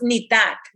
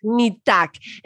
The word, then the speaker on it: Nitak: So that's Nitak. That it's pronounced Nitak. – Nitak.